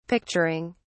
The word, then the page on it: picturing: British English